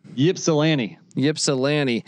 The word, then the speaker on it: Ypsilanti: They got to go up to Eastern Michigan here in Ysplanti or whatever. How do I say that? – Ypsilanti, Michigan.